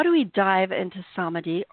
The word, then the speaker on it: samadhi: so the word in Sanskrit is pronounced samadhi, and it translates as enlightenment.